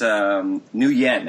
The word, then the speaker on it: Nguyen: I think it's Nguyen, actually. – Nguyen. – Yeah, you don't pronounce the G. – Don't pronounce the G. All right, so Nguyen.